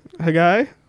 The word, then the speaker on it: Haggai: Haggai? Haggai? – Yep. – H-A-G-G-A-I? – Yeah.